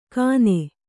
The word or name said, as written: ಖಾನೆ